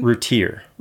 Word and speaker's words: Routier: D-A-R-L-I-E. – And how do you say the last name? – Routier.